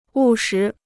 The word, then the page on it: 务实: wù shí